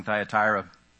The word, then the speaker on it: Thyatira: So thank you very much for reading that and pronouncing Thyatira correctly.